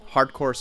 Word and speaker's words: Hardkour: Hardcore spelled H-A-R-D-K-O-U-R, and performance is spelled like performance. No special spelling there.